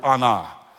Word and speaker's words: anah: Now this Hebrew word from 2 Kings, the Hebrew word of quarrel, picking a quarrel, is the Hebrew word anah. Anah, and it's literally pronounced like, oh no, like, oh boy, here we go. Oh no, here we go again.